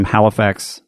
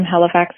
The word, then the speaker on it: Halifax: So this is Halifax, Nova Scotia, which I'm sure I'm pronouncing incorrectly. – Hey, Halifax, Nova Scotia.